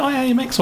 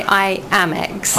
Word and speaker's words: IAMX: And you just picked five artists, which are The Gossip, Duran Duran, Kate Bush, Robots in Disguise, and IAMX. Which is it? IAMX or IMX? – I think they say IAMX.